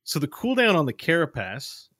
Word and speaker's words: carapace: you put emphasis in a weird way that made it seem dirty. – Carapace.